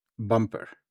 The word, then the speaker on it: bumper: La pronunciación fonética de esta es B-A-M-P-R. Bumper.